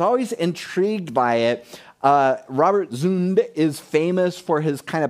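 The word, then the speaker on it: Zünd: It's by an artist called Robert Zund, Z-U with an umlaut over it. How do you say that? Is it Thank you. Zund. Yes, Robert Zund.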